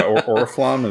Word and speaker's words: Oriflamme: Or as I want to pronounce it in French, Oriflamme. – Yeah, Oriflamme is how I pronounced it.